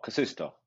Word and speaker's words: Kasisto: We have Casidio. Am I pronouncing it wrong? – No, Casisto.